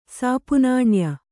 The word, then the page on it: ಸಾಪುನಾಣ್ಯ: kannada